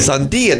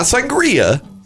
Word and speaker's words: Sandia: Sandia, not Sangria.